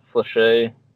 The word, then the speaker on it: fléché: But then when World War One of these words are French, so I think it's pronounced fléché and sabot.